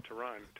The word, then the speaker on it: Tyronn: When he was playing, how I'm a name freak, so that's how I always remembered how to pronounce his name. Not Tyronn, but Tyronn.